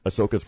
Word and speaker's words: Ahsoka: Ahsoka? – Mm-hmm. – Okay. – Yep. – Ahsoka's coming out.